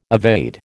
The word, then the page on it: evade: American English